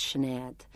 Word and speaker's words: Sinead: Well, I was taught by teachers from the west of Ireland, and it's a softer accent, so it's Sinead. Sinead O'Connor is the Dublin pronunciation.